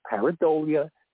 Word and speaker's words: pareidolia: I have a Catholic school nun ruler ready to slap the hand of anybody that tries to say it's pareidolia or something. You can look it up. It's pareidolia. There is no such thing, but that's the way the word's pronounced, that they made up for it.